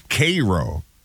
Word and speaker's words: Cairo: It looks like Cairo, but it's Cairo, Nebraska.